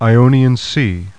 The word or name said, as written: IONIAN